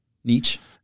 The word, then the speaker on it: Nietzsche: Nietzsche? – It depends.